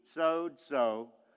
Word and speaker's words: sozo: there's a Greek word that's called sozo. And it's spelled S-O-Z-O, but it's in the English, we'd almost spell it like S-O-D, so-so, and it means to be, let me give you some of the definitions of it.